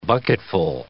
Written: bucketful

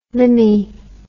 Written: limmy

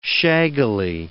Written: shaggily

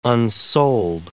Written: unsold

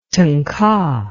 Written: tangka

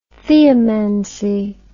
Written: theomancy

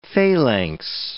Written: phalanx

